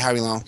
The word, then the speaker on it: Howie Long: Is it Howie Long? If I'm getting his name right. – Yeah, Howie Long.